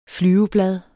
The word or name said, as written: flyveblad